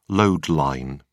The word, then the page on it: load line: British English